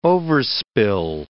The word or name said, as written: overspill